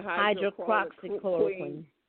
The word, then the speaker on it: hydroxychloroquine: Yeah, hydroxychloroquine.